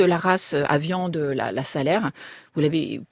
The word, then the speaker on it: Salers: Et puis, on va quand même dans le village de Salers. Salers, faut pas dire Salers. – On dit pas Salers. – On prononce pas le S à la fin, on fait pas son parisien.